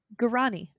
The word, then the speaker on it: Guarani: Specifically, it's from the, I meant to look at this pronunciation. I think it's Guarani people. – Okay. – Hold on. Guarani. Okay. I was pretty close.